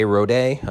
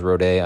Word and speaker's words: Rodé: Rodé. I believe his name is Rodé. I listened to the commentary and I think he pronounced it that way. It's R-O-D-E anyway.